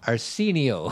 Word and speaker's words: Arsenio: It's A-R-S. – It's Arsenio Hall. – I know, but is it, how do you spell his name? – A-R-S-E-N-I-O. – Yeah, Arsenio. – But you say Arsenio. – Arsenio.